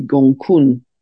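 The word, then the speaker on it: Gonggong: it's spelt Gongun, but it's pronounced apparently Gongkun.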